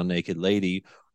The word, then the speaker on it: NKD LDY: And for those that don't know, it's it's spelled or stylized N K D L D Y. – Yes, yes. So naked lady without the vowels.